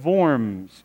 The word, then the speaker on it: Worms: But it's really pronounced the Diet of Worms. In Worms, Germany, the W is pronounced like the V.